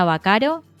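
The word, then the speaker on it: Baccaro: Eh, yo estoy en redes sociales como, me buscan como Mariana Bacaro, mi apellido se escribe con B corta y con doble C.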